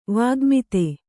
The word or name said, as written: ವಾಗ್ಮಿತೆ